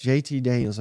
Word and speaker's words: JT Daniels: And yes, JT Daniels, I said that correctly.